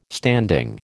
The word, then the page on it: standing: American English